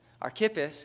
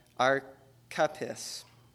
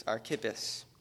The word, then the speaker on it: Archippus: And I'm going to be honest, Archippus, I had to look his name up because I knew that I was pronouncing it wrong, and I was. Archippus is how it is said. When I first read it, being a Mainer, I pronounced a lot of things wrong. And it sounded something like Archippus. Wrong. Not that. It's Archippus.